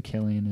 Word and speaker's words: Cillian: People think it's Cillian because it's a C. – Yeah. – I thought it was a C until I heard someone say his name and didn't correct him. So I assume Cillian is the way you pronounce it.